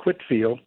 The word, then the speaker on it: Whitefield: Yes, there were actually George Whitfield, spelled Whitefield but pronounced Whitfield, came to the United States in the early about 1740, and was the first national celebrity.